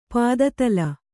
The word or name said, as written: ಪದತಳ